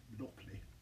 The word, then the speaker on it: Blockly: Blockly. – Blockly. Right. I don't call it blocky, it's blockly.